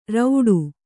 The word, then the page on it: ರೌಡು: kannada